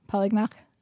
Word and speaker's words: Polignac: I think it's like Polignac. – Polignac? – Like not the neck. Polignac. I think the neck Polignac is like American.